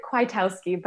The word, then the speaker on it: Kwiatkowski: I pronounce it Kwiatkowski, but I love every attempt at how people pronounce my name.